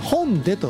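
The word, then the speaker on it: 弘大: はい、弘前のね、広いに大と書いて本でと読むね、本でという地名です。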